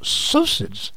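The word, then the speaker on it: psocid: It's formed by a little mite-like insects called psoucids. P-S-O-S-I-D. – Okay. – Yeah, that's right. – Silent P. – Psoucid.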